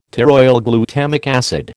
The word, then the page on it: pteroylglutamic acid: American English